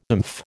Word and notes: oomph: American English

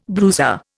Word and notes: bruiser: Indian

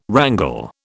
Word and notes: wrangle: British English

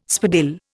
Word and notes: spadille: Indian

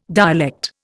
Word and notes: dialect: Indian

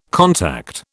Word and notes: contact: British English

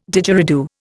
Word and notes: Didgeridoo: Indian

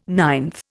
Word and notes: ninth: Indian

